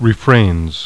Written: refrains

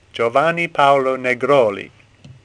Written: Negroli

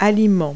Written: food

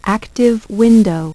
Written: active window

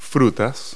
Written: fruit

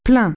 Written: full

